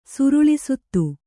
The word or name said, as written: ಸುರುಳಿಸುತ್ತು